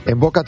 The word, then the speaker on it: Mvoca: En Boca tiene descuento del 20% para los oyentes de Esradio. Y en Boca se escribe M-V-O-C-A. En Boca. Primera consulta, estudio gratuito. Está en Velázquez 96. M-V-O-C-A.